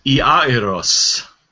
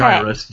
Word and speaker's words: Iairos: Well, The Greek is Iairos. – Okay. – Jairus, however you want to say it. – Iairos, who had a daughter. – Greek doesn't have a J sound, so that's right.